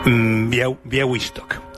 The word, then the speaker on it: Białystok: Se llama Białystok.